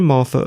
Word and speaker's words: Mathur: The show that you just listened to is produced, presented, and edited by me, Roy Martha, a writer. Martha is spelt M-A-T-H-U-R.